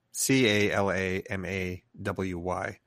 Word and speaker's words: Calamawy: Um, so I'm looking forward to the, the actress's name is May Kalama, Calamawy. – Am I uh, butchering that? C A L A M A W Y. – Calamawy. Okay. Um, she's great. – That's how I would pronounce it.